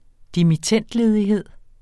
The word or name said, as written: dimittendledighed